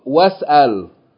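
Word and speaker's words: was-al (واسأل): So what we're going to do, we're going to join the Fatta of the Waw to the scene with sukoon, you're saying was-al. Was-al. Notice that was-al is the same word at the beginning of this line. Is-al. But when we begin with, we're pronouncing the vowel. Is-al. But if there's something before, we don't need the vowel. So we're just saying was-al.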